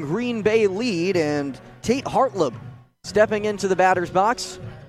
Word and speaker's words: Hartlaub: Hartlib, spelled H-A-R-T-L-A-U-B. The A is silent.